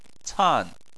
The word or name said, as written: can4